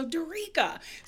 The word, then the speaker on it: Dorica: So Dorica. So I know that it probably doesn't look like Dorica when you read it.